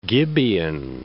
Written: Gibeon

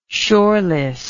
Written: shoreless

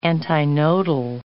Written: antinodal

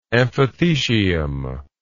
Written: amphithecium

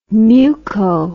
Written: muco

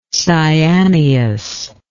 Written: cyaneous

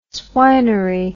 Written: swinery

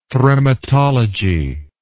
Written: thremmatology

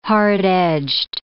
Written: hard-edged

